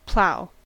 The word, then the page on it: plough: American English